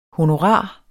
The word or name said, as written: honorar